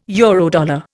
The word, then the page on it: Eurodollar: Indian